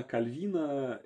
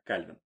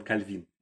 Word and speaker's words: Кальвин: Во-первых, я до этого момента целых 32 года жил и думал, что его фамилия читается как Кальвин. Но на самом деле он Кальвин. Он француз, поэтому он Кальвин.